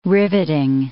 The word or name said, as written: riveting